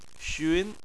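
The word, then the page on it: xun: tone 2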